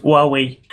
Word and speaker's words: Huawei: Xiaomi, Huawei, его правильно, я недавно знал, что его правильно Huawei, надо правильно говорить Huawei.